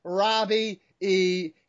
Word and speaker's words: Robbie E: Yes, I said that correctly. Robbie E.